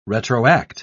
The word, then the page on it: retroact: British English